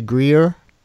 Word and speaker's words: Greear: Am I saying his name right? Is it J.D. Greear? – Mm-hmm. – G-R-E-E-A-R.